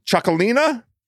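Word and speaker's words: Txakolina: I'm going to say it wrong. Chocolina. – Yeah. That's what I'm talking about. You nailed it.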